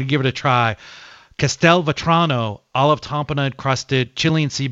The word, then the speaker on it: Castelvetrano: You did it great. I'll go with you on what the pronunciation for escabeche. I've always called escabeche, but we'll go with whatever it is. And then it's Castelvetrano. But I got to tell you,